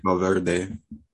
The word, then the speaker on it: Valverde: Valverde. I'm saying his name right. – Valverde.